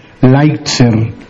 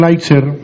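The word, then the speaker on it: Lighthizer: Ese representante, que tiene un apellido un poco difícil de pronunciar, Lightser, Light como luz, Z-E-R, Lightser. Este señor Lightser es el propuesto por la administración Trump, por el presidente Trump, que tiene que ser ratificado por el Senado.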